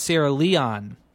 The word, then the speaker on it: Sierra Leone: It's pronounced Sierra Leone.